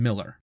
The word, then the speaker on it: Millar: By the way, that's spelled like Millar with an A, but she pronounces her name Miller.